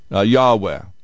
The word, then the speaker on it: Yahweh: Yahweh is how we would kind of pronounce it, although we still don't know what the vowels were because there were no vowels in the original Hebrew.